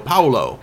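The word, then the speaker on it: Paulo: Are we pronouncing the U in your name? Because my brother's name is Paul. You don't pronounce the U. It's just Paul. – No. – P A U L, but just Paul. – No, it's not. So it's not Paul O. It's Paolo.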